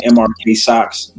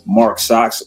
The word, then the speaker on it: MRK SX: Pronouncing MRK socks, Mark socks, just MRK.